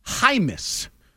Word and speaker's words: Jaimes: The second is offensive lineman Brendan Hymus. It's spelled J-A-I-M-E-S, but it's Hymus.